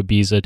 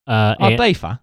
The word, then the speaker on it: Ibiza: They agree to go to Ibiza together. I refuse to pronounce it the Cockney way. – Ibiza.